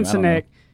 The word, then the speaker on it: Sinek: A great book to go get is Simon Sinek, and I always say his last name wrong. – No, you got it. – S-I-N-E-C-K. – That's how I say it. – Simon Sinek.